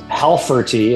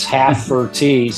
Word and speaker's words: Halferty: Okay, well, I have some new friends that I'm interviewing today, Bryan and Kandice, and last name is pronounced Halferty. It's Halferty, so you let me know right away.